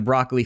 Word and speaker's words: Broccoli: Now, if you're wondering how to pronounce Broccoli, just always remember this. It was the Broccoli family. They not only are the producers of the James Bond franchise, but the family that invented, guess what? Broccoli, the vegetable.